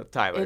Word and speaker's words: Tyler: No, Tyler.